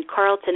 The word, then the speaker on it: Carleton: and Angelina is spelled A-N-G-E-L-I-N-A, and then Carlton is spelled C-A-R-L-E-T-O-N.